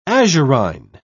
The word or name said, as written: azurine